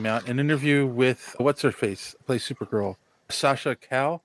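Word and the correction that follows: Calle: Kelly. – You pronounce the E? Okay. Sasha Kelly.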